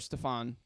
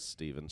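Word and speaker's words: Stephen: "Kiss and Tell" and Steven, uh, or Stefan, depending on how you want to pronounce yeah, it. Steven. She says Steven in the song.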